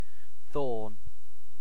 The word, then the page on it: thorn: British English